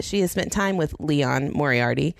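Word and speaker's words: Liane: Apparently, it's not Leanne Moriarty. It's Leon. – What? – As in Celine Dion. It rhymes.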